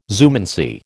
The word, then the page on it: zoomancy: American English